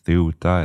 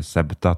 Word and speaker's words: Ceuta: Och jag vet att det här kan uttalas på lite olika sätt. Jag har sett Ceuta eller Septa till exempel. Men för konsekvensens skull kör jag på den här varianten.